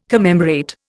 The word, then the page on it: commemorate: Indian